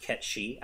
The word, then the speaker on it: Cait Sith: What was Cait Sith up to? – Yeah. – It's still wild to me that it's actually pronounced Ketchi.